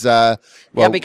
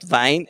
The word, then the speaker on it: Wien: It says, Wien. – Wien, it is Wien. Yeah, it's not Wein, it is Wien. Because Wein is, the German word Wein is written W-E-I-N.